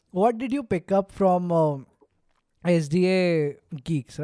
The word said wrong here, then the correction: SDN Geeks: I hope I said the name right. – SDN Geeks. – SDN Geeks.